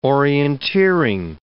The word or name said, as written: orienteering